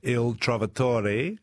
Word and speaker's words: Il Trovatore: Well, you've just come off, I noted here, a very successful run of Verdi, Il Trovatore, with the Melbourne Opera.